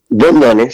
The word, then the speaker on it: Bondone: Así se pronuncia, Martín, porque nunca supe. Bondone. – Sí, sí. Bondone, sí, es italiano.